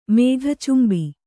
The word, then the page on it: ಮೇಘಚುಂಬಿ: Kannada